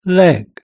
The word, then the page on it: leg: British English